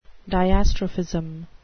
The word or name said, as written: diastrophism